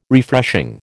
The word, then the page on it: Refreshing: American English